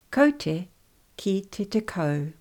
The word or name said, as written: Kaute ki te tekau